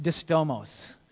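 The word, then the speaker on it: distomos (δίστομος): And so in this word two-edged, the word he uses here, it's this really odd Greek word, and it's pronounced distomos. So when he says God is giving us a two-edged sword, he's saying God has given us a distomos sword, okay?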